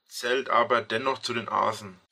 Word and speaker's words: Asen: Also es wird Asen ausgesprochen.